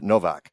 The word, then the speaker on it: Nowak: And I, I'm sort of the king of that because my last name is N-O-W-A-K and I pronounce it Novak.